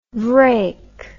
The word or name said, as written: vraic